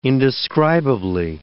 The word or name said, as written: indescribably